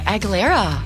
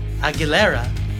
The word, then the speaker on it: Aguilera: So, today's song that I pick is from this movie, and it's Reflection by Christina Aguilera. Did I say that right? – Oh, yeah, Aguilera. – Aguilera.